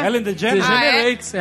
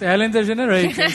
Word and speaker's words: Ellen DeGeneres: Na verdade, a Courtney ia ser Rachel, a Phoebe, a The Ellen DeGeneres, sei lá como é que fala. – Ellen DeGeneres. – Ellen DeGeneres.